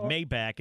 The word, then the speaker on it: Maybach: Well, the people say it's pronounced like Maybach or Maybach.